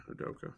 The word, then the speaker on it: Udoka: They go from one head coach in Udoka. I don't know if I said that right. – Yeah, Udoka.